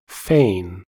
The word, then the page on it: fane: American English